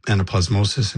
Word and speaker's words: anaplasmosis: How do I say it? Anaplasmosis? – That's right, anaplasmosis.